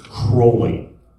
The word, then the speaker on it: Crowley: And he glared at me across the table and he said, "It's Aleister Crowley. His name rhymes with holy."